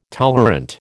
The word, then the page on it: tolerant: American English